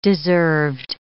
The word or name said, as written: deserved